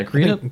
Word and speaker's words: Greedent: Yeah, Greedent. – Greedent. – It's like rodent, but green.